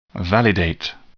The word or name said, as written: validate